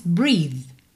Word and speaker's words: breathe: Mas a pronúncia é simples, breathe, breathe, ok? O E final é mudo.